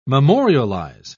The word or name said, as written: memorialize